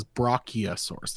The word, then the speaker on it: brachiosaurus: Okay, by the way, it is not bronchiosaurus. That's, I said it wrong. It is brachiosaurus. There's no N. So, close.